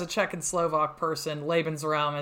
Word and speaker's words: Lebensraum: Lebensraum, Eleanor. Can you correct my pronunciation? – No, Lebensraum. Lebensraum is correct.